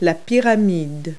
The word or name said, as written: pyramid